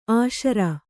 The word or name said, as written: ಅಶಿರ